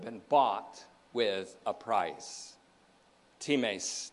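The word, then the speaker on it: times (τιμῆς): Since, says the psalmist, the price, same Greek word, times, T I M, long E S, the price of redeeming him is too costly.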